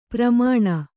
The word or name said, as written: ಪೆರ್ಮನೆ